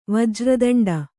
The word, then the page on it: ವಜ್ರತುಂಡ: vajra tuṇḍa